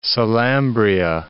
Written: Salambria

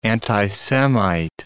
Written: anti-Semite

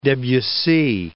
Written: Debussy

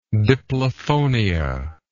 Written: diplophonia

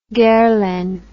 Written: Guerlain

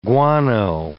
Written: guano

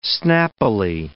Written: snappily

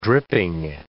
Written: dripping

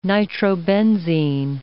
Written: nitrobenzene

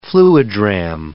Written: fluidram

